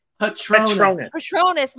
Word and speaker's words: Patronus: Patronus.